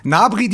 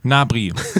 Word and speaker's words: Gnabry: Gnabry die Pfote. – Gnabry, so.